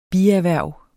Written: bierhverv

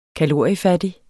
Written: kaloriefattig

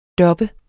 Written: dubbe

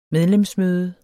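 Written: MEDLEMSMØDE

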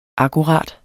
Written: akkurat

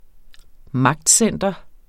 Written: MAGTCENTER

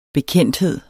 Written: bekendthed

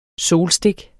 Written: solstik

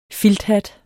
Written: filthat

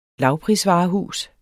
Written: lavprisvarehus

